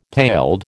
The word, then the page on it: paled: American English